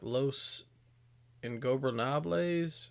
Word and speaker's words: Ingobernables: We had Claudio and Moxley representing the Blackpool Combat Club versus Preston Vance and Roosh representing Los Ingobernables, if I pronounce that correctly. – No, that was pretty good.